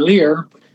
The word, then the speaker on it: Lyrre: I've heard it pronounced both ways, Lyre and Lear, and they're the hurdy-gurdy band.